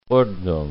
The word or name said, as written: Ordnung